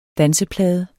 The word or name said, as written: danseplade